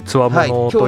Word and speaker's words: 強者: 冊子がございます強者と書いてつわものと読むんですけど、